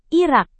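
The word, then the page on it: irak: indonesian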